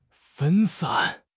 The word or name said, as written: 分散